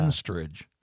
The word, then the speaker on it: Henstridge: Natasha Henstridge. Is that how you say her name? – Yeah. – Henstridge.